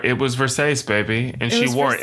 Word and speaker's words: Versace: So it's like, that was really the lesson that I wanted somebody to teach her, as opposed to like, it's pronounced Versace, as they eventually do.